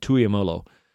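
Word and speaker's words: Tuimoloau: Tuiamalo. Low, I think it's low at the end. Tuiamalo.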